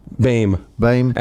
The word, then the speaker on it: Bohm: Jeff, mispronounce this, Boehm, B-O-H-M. – Bame.